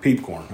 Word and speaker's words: Piepkorn: You say your last name. I want to make sure I hear you say it. – Peepcorn.